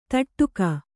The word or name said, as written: ಥಟ್ಟುಗಿ